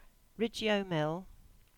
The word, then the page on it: Ridgeo Mill: English